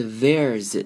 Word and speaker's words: there's: 这句要特别注意的是 there's 这个缩写，这边的一撇 s 呢，我们不会念成 s 的声音，而是会念成 z 这个声音，念成 there's this girl。